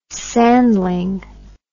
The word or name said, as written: sandling